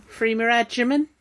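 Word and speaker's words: Freema Agyeman: sorry, I can never pronounce her surname. – Freeman Agyeman? – That's it, joining Torchwood.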